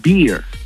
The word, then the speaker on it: beer: Porque con, o sea, la barba se pronuncia beard, el oso se pronuncia bear, ¿eh? Y la cerveza se pronuncia beer. Pero con R al final.